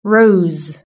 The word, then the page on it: rose: british-english